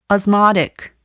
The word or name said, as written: osmotic